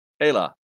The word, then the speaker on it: Ayla: A-Y-L-A. – Ayla.